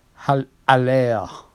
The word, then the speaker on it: Haller: If you say it correctly, because he's a Frenchman. Haller.